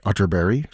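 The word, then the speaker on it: Atterberg: It's spelled Otterberg, but pronounced Otterberry, just in case you were Googling.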